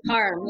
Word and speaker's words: Parham: Guys, why don't you give it up for Montreal sweetheart, Cara Palm. Did I say that right? – Okay. Hey, yeah, Parham.